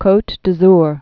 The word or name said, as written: Cote d'Azur